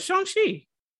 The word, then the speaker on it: Shang-Chi: Is it Shang or Shang? – You just said the same thing twice. – No, I didn't. I said it with a U instead of an A-U sound. – Shang. Shang. Yes, I think you put emphasis on the A in that Shang-Chi. I'm pretty sure that's how you say it.